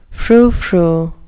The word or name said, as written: froufrou